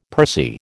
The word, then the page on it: pursy: American English